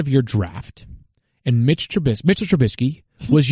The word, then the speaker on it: Trubisky: Oh, but we do know is that there's no T in his last name, and Matthew's not here to butcher that, so it's Mitchell Trubisky.